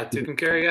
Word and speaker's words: Tucumcari: Uh, I passed through New Mexico, and it was right through Tucumcari. Is that how you say it? Yeah, Tucumcari.